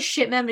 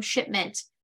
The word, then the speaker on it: Shipman: So you were talking about pronouncing things wrong, and I was like, his name is Shipman, but I keep calling him Shipment, and just can't let it go.